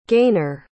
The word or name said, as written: Gainor